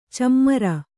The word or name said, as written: ಛೀಮಾರಿ